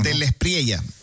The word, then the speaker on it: De la Espriella: Así es la pronunciación. – De la Espriella, así se pronuncia.